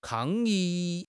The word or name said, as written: 抗议